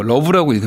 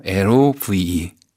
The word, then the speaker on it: L-O-V-E: 제 미니홈피 배경은 나탈리 콜의 이거 러브라고 읽으면 안 돼요. L-O-V-E.